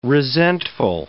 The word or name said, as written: resentful